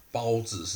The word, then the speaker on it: 包子: 那蒙古的包子哈，其实它的发音就是跟那个我们华人的包子是一样的哈，它叫包子，包子。